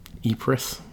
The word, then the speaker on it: Ypres: The third battle of Ypres, I think is the way you pronounce it. – Yeah. That's how you pronounce it, but yeah.